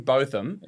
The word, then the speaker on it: Botham: The weekend prior, we had Sir Ian Botham.